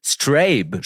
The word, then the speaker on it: Strabe: Strabe spricht man das Projekt aus. – Mhm. – S-T-R-A-B-E geschrieben.